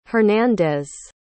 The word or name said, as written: Hernandez